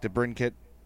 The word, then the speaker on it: DeBrincat: He says, which young Blackhawk, DeBrincat, oh, I said it right, Hartman, Schmaltz. – you said it right.